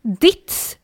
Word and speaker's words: Dietz: Du men hur uttalas det då? – Dietz stavas det. – Oj. – Eller D-I-E-T-Z.